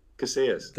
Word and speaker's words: Casillas: How do you pronounce it? – Casillas. – Casillas. Casilla. – Casillas. The double L is a Y.